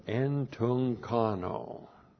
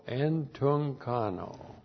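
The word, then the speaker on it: entunchano: Looks like that in the Greek, that's E-N-T-U. Now we got two gutturals. When you get them together, the first one you transliterate like an N, and then C-H-A-N, long O, entunkano.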